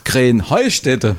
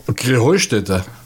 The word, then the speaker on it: Krähenheustätte: Und dabei heißt es doch Krähenheustätte, oder wie? – Krähenheustätte. – Ja, genau, und das ist die Betonung auf dem Heu.